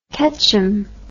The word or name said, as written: ketcham